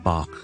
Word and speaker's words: Bach: 好，第一个我哋呢音乐之父巴哈呢，我哋通常係读 Bach。